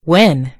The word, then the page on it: win: British English